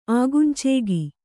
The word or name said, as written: ಆಗುಂಚೇಗಿ